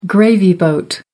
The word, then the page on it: gravy boat: American English